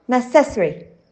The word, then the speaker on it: necessary: And number 10 in our super list is necessary, necessary. As the middle syllable is the longest in the word necessary, many people are tempted to give it the strongest emphasis, right?